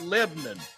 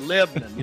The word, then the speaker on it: Lebanon: They pronounce it Lebanon. If you ever been there, that's how they say it, Lebanon.